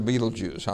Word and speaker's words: Betelgeuse: But it's actually Betelgeuse.